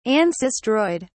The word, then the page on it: ancistroid: British English